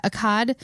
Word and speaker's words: Akkad: I think they're more of an A sound. – Yeah, Akkad. It's A-K-K-A-D.